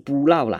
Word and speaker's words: 浮羅: 哦，尤其如果不是马来西亚人的话，可能搞不太懂，因为实际上“ 弗罗” 这两个字，你要用闽南语来发音，哦，就接近不绕啦，